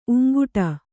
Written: ಊಂಕಿಡು

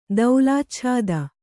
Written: ದೌಲಾಚ್ಛಾದ

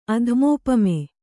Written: ಅಧಮೋಪಮೆ

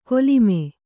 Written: ಕೊಲಿಮೆ

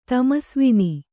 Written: ತಮಸ್ವಿನಿ

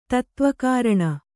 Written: ತತ್ತ್ವಕಾರಣ

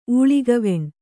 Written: ಊಳಿಗವೆಣ್

